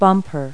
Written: bumper

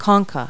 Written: concha

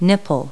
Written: nipple

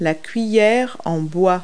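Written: wooden spoon

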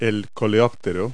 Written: beetle

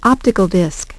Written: optical disk

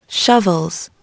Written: shovels

